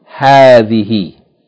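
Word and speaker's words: hadhihi (هذه): Yani, this word is pronounced hadhihi, hadhihi, with a long vowel.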